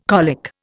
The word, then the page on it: colic: Indian